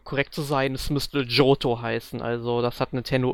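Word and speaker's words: Johto: Aber es wird tatsächlich Joto ausgesprochen.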